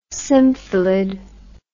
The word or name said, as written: symphilid